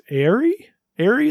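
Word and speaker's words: Ary: I don't know if it's Aerie in The Secret of Seasons. Is that how you pronounce it? It's A-R-Y.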